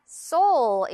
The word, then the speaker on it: Seoul: 서울이 아니라 Seoul. 이렇게 도레미파솔처럼 발음을 합니다.